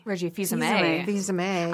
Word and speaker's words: Fils-Aimé: But but there was also another interview with the Nintendo of America president, Reggie Fils-Aimé.